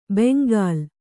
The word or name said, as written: ಬೆಂಗಲ್